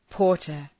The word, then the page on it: porter: ‘pɔ:rtər